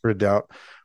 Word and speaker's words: Redoubt: Redoubt. – So that's REDOUBT. It looks like redoubt, like I doubt this.